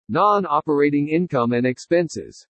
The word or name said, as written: NON-OPERATING INCOME AND EXPENSES